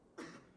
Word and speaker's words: Fletes: Fletes. – Say it again? – Fletes. – Spell it for me. – F-L-E-T-E-S.